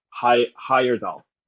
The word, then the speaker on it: Heyerdahl: Christopher, let's say his name, Heyerdahl. – Okay. I want to see, because I was thinking a different actor that, you know, you might have said Christopher Heyerdahl. Okay. Canadian actor, Christopher Heyerdahl.